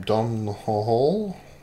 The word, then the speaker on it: Domhnall: Dom Hall. See, I never knew how to pronounce his name. Dom Hall? It's like DOMHNALL.